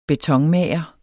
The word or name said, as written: betonmager